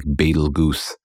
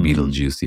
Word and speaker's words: Betelgeuse: It looks like you might pronounce it like Betelgeuse or something, but it's pronounced Betelgeuse.